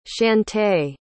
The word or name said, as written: shantay